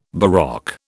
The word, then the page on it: baroque: British English